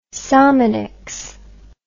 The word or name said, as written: sominex